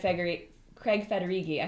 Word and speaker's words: Federighi: And Craig Federighi, I hope I'm saying that right. – Yeah, you got that.